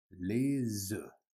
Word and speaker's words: œufs: Okay, so this one is confusing, so the F is silent. S is silent, okay, and this is just E, and the S connects. Les œufs.